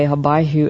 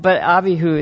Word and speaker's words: Abihu: By the way, I noticed that Robert read what we usually say, Habayhu, in the Hebrew way. That's because he's taking Hebrew. That's how we usually pronounce it, but Abihu is how you would pronounce it in Hebrew.